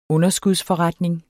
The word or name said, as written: underskudsforretning